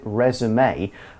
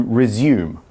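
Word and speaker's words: résumé: And when you use this word resume, make sure you put the accents over the two E's and pronounce it resume. Otherwise, it turns into resume, which is a verb and a different word.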